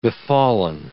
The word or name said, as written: befallen